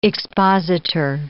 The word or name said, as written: expositor